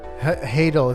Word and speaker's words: Hadal: Hadal, it's like tidal.